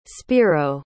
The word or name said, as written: spiro